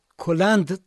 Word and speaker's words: کلند: بله، البته کلند تلفظ میشده.